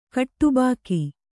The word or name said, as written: ಖಟಬಾಕಿ